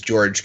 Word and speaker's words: Jorge: Um, there there is a a pitcher uh, for the Marlins uh, whose name is George Guzman, and George is spelled J O R G E.